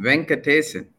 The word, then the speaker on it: Venkatesan: How do we say your last name, please? – Chris, you got it right the first time. Venkatesan.